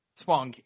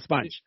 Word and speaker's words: Spong: How do you say his name? Spong? – Spong, yeah. – You sure it's not Sponge? – I'm positive, yes.